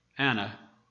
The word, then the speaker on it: Anah: The word afflict in Hebrew it's spelled A-N-A-H, Anna, and is translated humble.